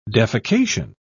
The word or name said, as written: defecation